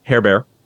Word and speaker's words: Herbert: And the marionette for, I'm going to butcher his last name, Hebert, spelled Herbert, their physics teacher, Hebert.